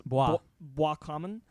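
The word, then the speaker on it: Boiscommun: I'm pretty sure because it's French, it's Bois Common.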